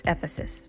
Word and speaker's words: Ephesus: It's Ephesus. And we pronounce it Ephesians, but we pronounce it Ephesus.